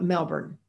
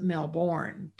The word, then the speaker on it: Melbourne: Typically, most people here who live here call it Melbourne, B-U-R-N. But a lot of people pronounce it Melbourne, B-O-R-N.